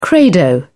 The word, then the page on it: credo: British English